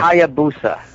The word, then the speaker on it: Hayabusa: Hayabusa.